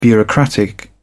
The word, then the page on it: bureaucratic: British English